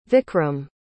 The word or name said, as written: Vikram